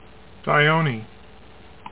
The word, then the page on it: Dione: dy OH nee